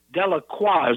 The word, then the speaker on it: Delacroix: Anyway, the great painter Delacroix is the correct way to pronounce it.